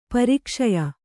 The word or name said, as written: ಪುರಕ್ಷಯ